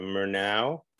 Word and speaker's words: Murnau: So it says that you're located in the Bavarian Alpine foothills, in the artist town of Murnau, is that how you say it? – Yeah, exactly.